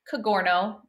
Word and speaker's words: Cogorno: Cogorno Golf, C O G O R N O. It rhymes with DiGiorno.